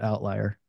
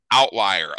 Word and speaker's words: OVTLYR: It's called Outlier. So it's the V would actually be like a U. So we pronounce it Outlier. – Yep. OVTLYR. Outlier.